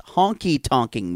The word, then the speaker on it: honky tonkin': I think you're saying it wrong. You've had it as Honky Tonkin' mix. – Yeah. – Instead of honkin' tonkin' mix, which you keep calling it. – Oh, it's honky tonkin'. That's how you say it.